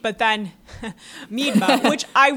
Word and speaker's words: Miedema: And before the last podcast, Allison and I looked up on several videos how to say her name, and there was like a how to pronouncer, and it was like Miedema.